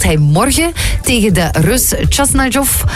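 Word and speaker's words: Khachanov: Chasnajov. – Chasnajov. – Volgens mij was dat vlekkeloos uitgesproken.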